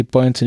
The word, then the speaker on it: Buoyancy: Ich kann irgendwie Buoyancy nicht aussprechen. Also das, das, das. – Beim zweiten hast du es jetzt richtig hingekriegt, Buoyancy, wenn das auf Deutsch.